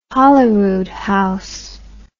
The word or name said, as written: Holyroodhouse